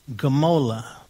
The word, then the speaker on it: Gmolah: This is one of the names of God, but it's the name Jehovah Gamola. Jehovah Gamola, and that's spelled G-M-O-L-A-H. Jehovah Gamola. Sounds like guacamole, but it's not. It's Jehovah Gamola.